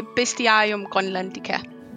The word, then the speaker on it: Bestiarium Groenlandica: How do you say it properly? – I say Bestiarium Groenlandica." – Bestiarium Groenlandica.